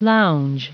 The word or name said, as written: lounge